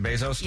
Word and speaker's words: Bezos: I read the other day that Jeff Bezos, is that how you say it, Bezos? – Yeah.